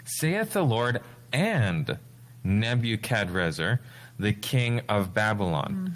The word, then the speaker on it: Nebuchadrezzar: have the families of the north and Nebuchadrezzar. – Mm-hmm. – And I'm saying it like that because that's how it's pronounced in Jeremiah here. That's how it's spelled in Jeremiah.